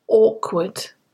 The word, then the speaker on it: awkward: The next one's an adjective, and it's awkward. Awkward. Pronounced with an or sound at the beginning.